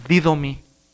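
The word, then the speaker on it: didomi (δίδωμι): El verbo dar en griego suena así, didomi, es el verbo dar.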